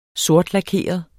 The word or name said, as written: sortlakeret